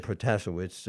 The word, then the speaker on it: Protasiewicz: And Janet Protasiewicz, the Democrat, characterized as a quote-unquote liberal, won by 55-45% with about 95% of the vote already counted.